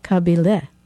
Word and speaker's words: Kabile: And last night in Boulder, Donka says, the Americans call our band, Kabile. But actually in Bulgarian, it's pronounced Kabile.